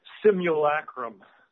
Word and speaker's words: simulacrum: Simulacrum. Am I saying that right? – Yeah, simulacrum.